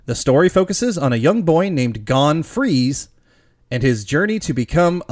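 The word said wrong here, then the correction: Freecss: It's Freaks.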